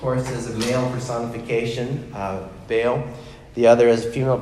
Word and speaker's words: Baal: Baal in particular, if you want to do a Hebrew pronunciation, it's Baal.